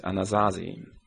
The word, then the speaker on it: Anasazi: Also here the theme is interesting, as players try to uncover the secret treasures of the mysterious Anasazi, that's how we pronounce them in German, a North American native tribe who vanished in the 13th century.